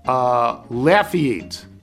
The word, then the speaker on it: Lafayette: The home of Ron Guidry, Lafayette, Louisiana, is pronounced Lafayette. – Lafayette. Lafayette? – Lafayette. L-A-U-G-H, emphasis on laugh, E-E-E-T.